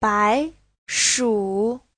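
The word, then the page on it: 白薯: bai2shu3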